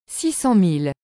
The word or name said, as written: 600000